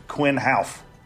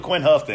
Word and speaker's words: Quinn Houff: So, but how did we pronounce this guy's name wrong for so long? I listened yesterday to driver intros, and they pronounced it Quinn Houff. How we owe this guy an apology? We've been the wrong guy for 2 years.